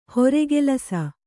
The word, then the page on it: ಹರಕಲಿಸು: harakalisu